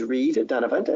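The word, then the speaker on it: Reid Dunavant: My name, as you said, is Reed Dunavant, and I'm going to spell that for you. It's R-E-I-D-D-U-N-A-V-A-N-T.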